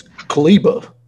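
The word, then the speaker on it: Kleber: Kleba? – Yeah. – Okay, no ER. Got it. – It's Kleba. – Kleba.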